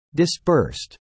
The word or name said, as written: dispersed